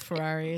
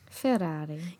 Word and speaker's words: Ferrari: Like, if things heat up with Ferrari, it's gonna... Ferrari. – They will. – I'm so sorry, I said it wrong. – Ferrari.